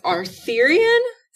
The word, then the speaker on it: Arthurian: So this one is, mispronounce this, it's Arthurian.